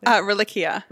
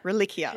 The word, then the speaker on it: Reliquia: So you saw a gap in the market when it comes to Reliqua. – Yes. – Am I saying that properly? – Relicia. – Relicia.